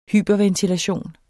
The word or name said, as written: hyperventilation